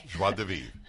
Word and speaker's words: joie de vivre: It's pronounced okay. – Joie de Vivre.